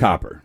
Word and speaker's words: Copper: Because I used to always say Cooper, and her name. – It's Copper.